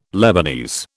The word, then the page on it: Lebanese: British English